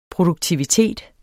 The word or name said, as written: PRODUKTIVITETEN